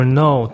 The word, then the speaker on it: know: 比如 know to know 知道，它以前有个科，它肯定以前是是念 know，对吧？不然的话它不会写上去。